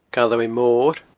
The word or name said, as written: Galloway Mor